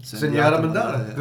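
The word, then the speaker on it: Zenyatta Mondatta: Zenyatta Mandata.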